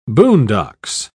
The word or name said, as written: boondocks